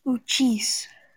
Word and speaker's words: Uchis: C-H-I-S. – Uh-huh. C-U-C-H-I-S. How do you say that word? – Uchis.